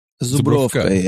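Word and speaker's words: Żubrówka: Zubrovka, yeah.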